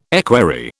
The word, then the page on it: equerry: British English